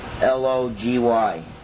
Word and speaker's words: Hamartiology: It's Hamartiology.